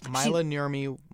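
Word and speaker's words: Maila Nurmi: And you've taken this love of these horror movies into the real world, befriending, is it Myla Nurmi? Is that how you pronounce her name? – Yes. Myla Nurmi was in the film Plan 9 from Outer Space.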